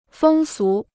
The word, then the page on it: 风俗: fēng sú